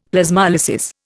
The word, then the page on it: Plasmolysis: Indian